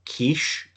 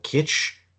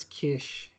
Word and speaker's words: kitsch: but apparently camp art is often confused with quiche. I can't even pronounce that word. Kitsch. K-I-T-S-C-H. – I think it's kish, yeah.